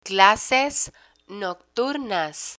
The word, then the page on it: clases nocturnas: spanish